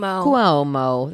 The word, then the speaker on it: Cuomo: Well, I don't know if you heard this, but I found out that the New York governor, Andrew Cuomo, is that how you say it? – Cuomo. – There you go.